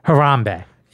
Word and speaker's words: Harambe: Harambee.